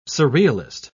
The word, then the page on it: surrealist: British English